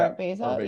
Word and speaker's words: Art Basel: That's how you say it, right? Yeah, Art Basel.